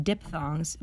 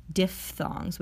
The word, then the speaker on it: diphthongs: Finally, our last topic of phonetics for today the diphthongs, not pronounced diphthongs, but instead, if you want to truly sound like a fancy speech pathologist, diphthongs with a f sound.